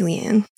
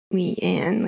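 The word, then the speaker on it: Leane: It is Leanne. I don't know how else. Like, it's Leanne. Like, yeah. – I like to take the Brandon Sanderson approach of, you know what, pronounce it however you like. – Okay, well, I'm going to continue to pronounce her Leanne, regardless of how Varen pronounces her name in this episode, because I cannot wrap my brain around how Varen says it.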